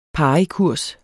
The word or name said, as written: parikurs